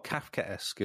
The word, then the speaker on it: Kafkaesque: How do you pronounce it? Kafkaesque. Oh, Kafkaesque is how you pronounce it.